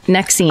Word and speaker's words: NXIVM: That's how you pronounce it. Nexim. – Oh, that's the branding one. – That's right. So Nexim might not sound familiar, but it's NXIVM.